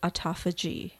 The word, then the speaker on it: autophagy: This week we're talking about something called autophagy. – Autophagy. – Yeah, let me spell that for us. It's A-U-T-O, like auto, and then P-H-A-G-Y.